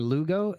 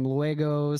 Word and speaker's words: Lugo: And that is Sam, S-A-M, that's my first name. And then Lugo is Luis Gonzalez. So it was like my name broken down. – Okay. Yeah. Okay. – But people kept mispronouncing it. Sam Luego, Sam Lego.